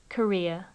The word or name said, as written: career